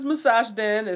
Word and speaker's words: Musashden: Nah, my online name is Massage Den. It's spelled MUSASHDEN. Specifically that way.